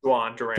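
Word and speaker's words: Jhoan Duran: But back into their bullpen, they have two closers essentially with Jorge Lopez they got from Baltimore last year, and then is it Joan Duran? How do you say his first name? – Juan Duran. Took a lot of practice to get that one down.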